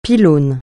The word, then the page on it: pylône: francais